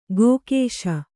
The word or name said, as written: ಕಕ್ಕಶ